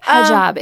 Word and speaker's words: hijab: And you, am I saying this correctly? You wear a hijab? – Hijab in many of your scenes.